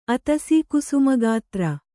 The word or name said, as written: ಅತಸೀಕುಸುಮಗಾತ್ರ